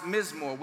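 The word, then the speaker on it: mizmor (מזמור): The Greek is pronounced psalmos, but in the Hebrew, it's pronounced mizmor, which means song.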